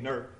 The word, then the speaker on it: Newark: It was in August of 1979, and we went to Newark, Ohio, or as they say up there, it's Newark, Ohio. N-E-R-K, Newark. That's not the correct spelling, but that's how they pronounce it.